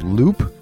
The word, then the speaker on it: Loup: And this song is called Loop, which is spelled L-O-U-P.